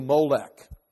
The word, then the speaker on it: Molech: Do not give any of your children to be sacrificed to Molech, or milk, M-L-K, and that's why I would milk. But there's syllables there, so there would be vowel sounds. We just don't know what those vowel sounds would be.